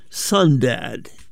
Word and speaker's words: Sundad: We now continue that mood with music by a duo called Sundad. That's spelled S-U-N-D-A-D.